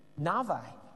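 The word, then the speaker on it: Navi (נביא): Well, the Hebrew word for prophet is a word that's spelled N A B I, but it's pronounced, the B's are V's, Navi, meaning someone who speaks before.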